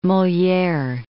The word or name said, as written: Moliere